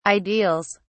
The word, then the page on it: ideals: British English